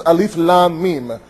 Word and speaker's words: Alif Lam Mim: Not all of them, but for example, Surah Baqarah begins Alif Lam Mim. It's written like a word but pronounced letter by letter.